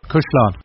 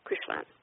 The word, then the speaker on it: cuislean: And also he gave me something that is right at the beginning of the book, and my pronunciation will not be right, but it's the word C-U-I-S-L-E-A-N. – Cuislán. – Yeah, Cuislán, which means both the beat of a heart and the flow of the river.